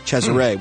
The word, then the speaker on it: Cesare: Cesare, which I believe is the Italian pronunciation of Caesar.